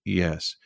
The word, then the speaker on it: Hawes: Hawes, which if you pronounce it with anything like an English accent is indistinguishable from the word that is Whores.